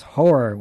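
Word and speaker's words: HO99O9: It's, what is it, H-O-9909, and it's pronounced Horror with Bone Collector.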